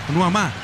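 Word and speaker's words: Nuamah: Nuamá, está bien pronunciado. Nuamá, sí, tal cual, muy bien pronunciado, obvio.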